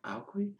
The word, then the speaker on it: Aequi: A-E-Q-U-I? – Alqui.